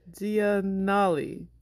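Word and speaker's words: Giannulli: But that didn't stop Lori, because a year later, in 1997, she eloped with her now husband, fashion designer Massimo Gianluni. Gianluni? I said it? No? – Giannoli. – Giannoli. Giannoli.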